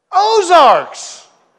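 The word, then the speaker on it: Aux Arcs: We cut through there and we noticed a sign that said that there was some Arkansas tourism kind of a attraction, a bridge called AUXARC. That's French. I don't know how you pronounce that. No Frenchman in here, obviously. AUXARC. Ozarks? Ozarks! Okay, that's what it means, Ozarks.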